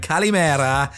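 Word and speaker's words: Calimera (καλημέρα): And Calimera is exactly how to say it.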